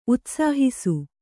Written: ಉತ್ಸಾಹಿಸು

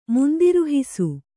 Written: ಮುಂದಿರುಹಿಸು

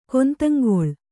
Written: ಕೊಂತಂಗೊಳ್

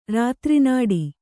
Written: ರಾತ್ರಿನಾಡಿ

